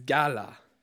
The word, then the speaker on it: gaal: That word there in Hebrew, I may pronounce this wrong, is Gala. G-A-A-L.